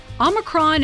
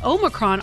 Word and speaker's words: Omicron: If you were wondering how to pronounce it, though, apparently both Omicron and Omicron are fine. And then the Oxford Dictionary actually includes Omicron. And in classical Greek, it was pronounced with the second syllable, sounding like a me.